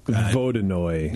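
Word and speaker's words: Vodyanoy: We made a Russian Imperial Stout early on, and we loved the creature, but its name is Vodynoy, and it's spelled Vodynoy. But it's like Vodynoy.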